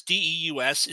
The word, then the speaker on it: Deus: Deuce was the third, or Deuce, or Deus, D E U S, is that's how it's spelled, D E U S, which, when pronounced Deus, is the Latin word for God.